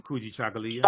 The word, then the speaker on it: Kujichagulia: And so with this year's uh, summit, we're focusing on um, self determination, um, Kwanzaa, Kuji, Chagalia, excuse me for Kuji, mispronouncing. Kujichagulia? Um, yes.